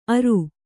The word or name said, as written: ಅರು